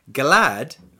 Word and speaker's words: glad: And in Svenska, glad rhymes with sad.